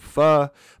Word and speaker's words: pho: So Pho, P P-H-O, pronounced pho, is a soup that comes from Vietnam, but has kind of made its way throughout Southeastern Asia.